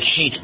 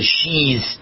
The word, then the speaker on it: Sheetz: You pronounce it like Sheetz, but it's S-H-E-T-T-Z. So I thought it was Sheez, but it's Sheetz.